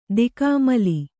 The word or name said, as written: ದಿಕ್ಕಾಮಲ್ಲಿ